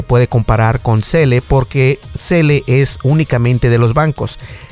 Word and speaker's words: Zelle: Se llama Cele y se escribe Z-E-L-L-E.